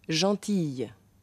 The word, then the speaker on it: gentille: Hier spricht man ein I. Gentille.